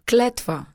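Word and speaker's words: Kletva (Клетва): So how do you say its name? – Kletva. – Okay, just like it looks.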